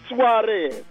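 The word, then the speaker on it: Suárez: Luis Suarez.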